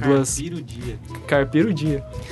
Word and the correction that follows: Carpe diem: Carpe diem.